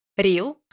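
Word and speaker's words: Re-l: ее имя так и пишется Реал. Я очень долго смеялась. – Там на самом деле Реал, но А еще выпадающее, поэтому иногда там Рил просто без А.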